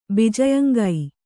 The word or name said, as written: ಭೋಜ್ಯಾಂಗ